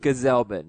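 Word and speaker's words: Gsellman: But this was definitely a relieving start for Robert Gazelman for me. – Gazelman. – Gazelman. – Gazelman, yeah. Hard G.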